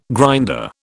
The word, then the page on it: grinder: British English